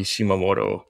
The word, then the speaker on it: Shimamoto: And it's Donnie Shimamoto, just under that. The last name is spelled exactly as it sounds.